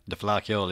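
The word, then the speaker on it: Fleadh Cheoil: Have I pronounced that correctly? – The Flair Kill, yeah.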